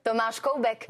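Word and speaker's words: Tomáš Koubek: Nous on disait Thomas Koubek et vous dites... – Il est tchèque. – Absolument. – Comment vous prononcez ? – Thomas Koubek.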